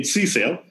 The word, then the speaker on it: CSAIL: Now, I'm always fascinated by the, it's referred to as, is it CSAIL now? Is that the right way to pronounce the acronym for computer science? – It's CSAIL.